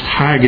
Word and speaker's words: chag: The second Hebrew word that's translated into the English word feast in Leviticus 23 is, I think it's pronounced hag, it's C-H-A-G, Strong's 2282, Chag, Hag, I believe it's pronounced.